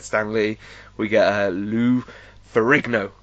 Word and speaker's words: Ferrigno: I got that right. – You got that right. – I didn't just butcher his name. Like, yeah, Matt will know the amount of times I try to say that name. Faringo.